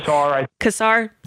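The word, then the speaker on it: Casar: is it Kasar? Is that how you say his name? Kasar? – That works. – Okay. – Kasar.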